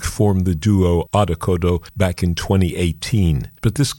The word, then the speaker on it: Odd Okodo: And they, in fact, are called Odd Okodo.